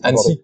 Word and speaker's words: Annecy: Et euh, question plus euh, plus pratique, en ce euh, en en on est déjà en quoi, pardon En Ancenis, euh, la ville euh, Annecy.